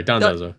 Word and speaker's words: Dondozo: Oh, we should explain what Dodonzo is. – Yes, please. – Or Dondozo. Sorry, Dondozo. Michael said it wrong. Michael said it wrong for like a day, and we can't say it right now.